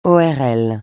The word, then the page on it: ORL: francais